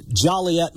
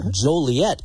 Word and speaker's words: Joliet: It's like Joliet, Montana. It's Joliet in Illinois, but it's Joliet, Montana.